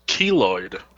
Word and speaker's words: keloid: Keloid.